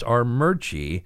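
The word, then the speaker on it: Armuchee: You have to understand that there is no R. There is no second R. It is spelled R. Murchie. But it is pronounced like all, apparently, town names in Georgia completely differently than it's spelled.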